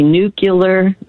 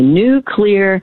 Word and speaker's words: nuclear: And then the third one that people mispronounce all the time is when they say nuclear, not correct. So if it's cloudy today but tomorrow it's going to be a new clear day, just say nuclear and you will pronounce nuclear correctly.